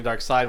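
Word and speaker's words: Darkseid: Well, I've always called him Dark Seed at first, and then I started calling him Dark Side once I realized that's how everybody says that you're supposed to pronounce Seed.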